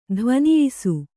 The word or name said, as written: ಧ್ವನಿಯಿಸು